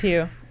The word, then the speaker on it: Pugh: It's Pugh. – Adam? – I think we're at a crossroads. – It's Pugh, like the church pew.